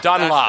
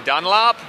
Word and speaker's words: Dunlop: Dunlop. That's with a British accent. So Dunlop. – So with the American style, it will be Dunlop.